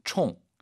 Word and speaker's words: 冲: 冲, 총. 이것도 이제 총이라는 단어 요때 이제 사성이기 때문에 그대로 말투나 태도가 거칠다 해서 총.